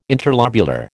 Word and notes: interlobular: American English

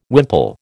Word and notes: wimple: American English

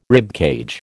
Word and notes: ribcage: American English

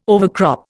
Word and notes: overcrop: Indian

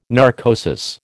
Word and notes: narcosis: American English

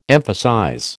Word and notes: emphasize: American English

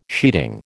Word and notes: sheeting: American English

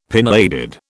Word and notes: pinheaded: British English

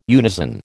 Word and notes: unison: American English

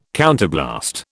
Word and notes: counterblast: British English

